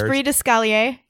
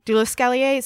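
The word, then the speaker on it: l'esprit de l'escalier: It's l'esprit, so it's L'esprit. – L'esprit. – The spirit. L'esprit. And I believe it's it might be d'escalier, de l'escalier. So d'escalier. Escalier is French for staircase.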